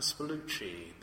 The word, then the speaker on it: Spallucci: I'm so glad to hear you say it out loud. I've always read it and never knew how to pronounce his name, Spallucci.